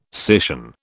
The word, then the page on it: scission: British English